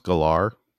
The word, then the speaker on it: Gellar: She has said it's pronounced Gellar, and we can all choose to pronounce it Geller and be wrong, but that's not what she wants.